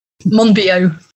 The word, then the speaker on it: Monbiot: Monbiot.